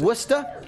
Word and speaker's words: Worcester: Yes, in Worcester, Massachusetts. – Worcester. – W-U-S-T-A, as we pronounce it.